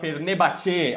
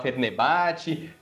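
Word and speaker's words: Fenerbahçe: É, você fala Fernebate ao invés de falar Fernebate, Bejictas ao invés de Besiktas.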